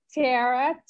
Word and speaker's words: Tera: Minister, where Ashley is MIN, which is for minister, Tara, T-E-R-A, and my middle name, Nikanya. And Nikanya is spelled N-I-K-O-N-Y-A, Minister Tara Nikanya.